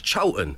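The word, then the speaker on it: Chorlton: And I was like, whoa, it's Cholton. don't you dare pronounce it like it's spelt. It's Cholton.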